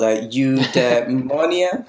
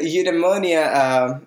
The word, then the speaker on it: eudaimonia: It's called eudaimonia. – Okay, I'm not even going to say it, so I'll just let you say it. – So it's a eudaimonia, it's a word used by Aristotle for happiness, and the word doesn't directly translate into happiness.